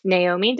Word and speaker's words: Naomi: I'm really excited because today we're talking with my friend Naomi.